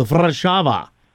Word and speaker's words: Warszawa: But here's the thing, Poland is the same, Polish, where the W is pronounced V. So the city that we call Warsaw, when you're actually in Poland, is called Warszawa.